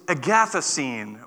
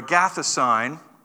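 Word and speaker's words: agathosine (ἀγαθωσύνη): It is agathosine or agathosine, depending on which hood you come from in Greece.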